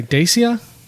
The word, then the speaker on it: Dacia: Is it, what would you, D-A-C-I-A, Dacia? – Dacia Williams.